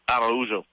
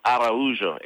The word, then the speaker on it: Araujo: Araujo. – Repeat that again, because I don't want to butcher it. – Araujo, A-R-A-U-J-O.